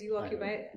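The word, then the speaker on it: UOQPI: And it's U-O-Q-P-I, but we pronounce it as You Occupy, as most people can't pronounce it.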